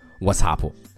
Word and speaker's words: WhatsApp: 上面我们还说到了微信，那在国外有一个和微信差不多的，叫 WhatsApp，W H A T S A P P，WhatsApp。那在之前的公众号里面，我讲了一个打招呼的表达，叫 What's up，What's up。那这个 app 的英文是 WhatsApp，WhatsApp。它后面的元音由 a 变成了 a，WhatsApp。但是无论如何，你可千万不要读成 WhatsApp。